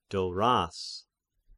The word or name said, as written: Doras